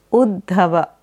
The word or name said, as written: Uddhava